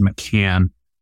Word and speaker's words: McCann: A lot of times people say McCain. People get it mixed up with John McCain. So I've gotten pretty used to people mispronouncing my last name, but it actually is McCann.